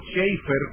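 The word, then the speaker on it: Chafer: De hecho, Lewis Sperry Schaeffer, o Schaeffer, como se debe pronunciar en inglés,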